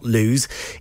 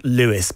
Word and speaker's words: Lewes: It is not Lewes, it is Lewes.